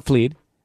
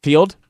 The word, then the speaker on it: Feeld: Field, the dating app. See, that's the reason, because there's two E's. So I see Fleed, not Field. It's spelled wrong. It's spelled F-E-E-L-D, Field.